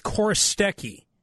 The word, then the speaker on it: Chorostecki: So my friend's last name is Korostecki, and it's spelled Chorostecki.